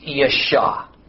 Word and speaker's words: Yesha: The Hebrew root word that's formed yud, shin, ayin, or YS, like the SH sound, and then either a silent or nearly silent A sound, is usually pronounced Yesha.